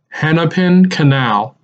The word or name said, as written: Hennepin Canal